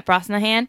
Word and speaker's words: Brosnahan: And I think Rachel Brosnahan is how you say it? – Brosnahan, I think. – Yeah, Brosnahan.